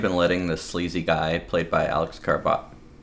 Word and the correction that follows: Karpovsky: I have it written down. And I pronounced it earlier. Karpovsky. There we go.